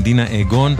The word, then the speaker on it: Dina Ögon: כותבים אותה דינה אוגון, אבל אומרים דינה אגון.